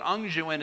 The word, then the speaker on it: unguent: Now, unguent, I'll explain it, but unguent is U-N-G, okay, I have to spell it in my head to say it. U-N-G-U-E-N-T, unguent, or a smearing, like an ointment.